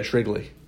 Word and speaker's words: Shrigley: Yeah, Shrigley. Kind of like Shrigley's gum.